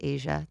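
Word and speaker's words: Aijia: My name is Asia.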